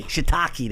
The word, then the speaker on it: Shiitake: Shiitake. – Shiitake, that's it.